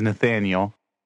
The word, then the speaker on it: Nathaniel: but my legal name is Nathaniel.